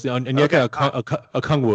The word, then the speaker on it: Onyeka Okongwu: Onyeka Okongwu, is that how you pronounce it? Am I close? – Yeah, kind of close. Onyeka Okongwu.